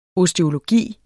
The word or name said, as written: osteologi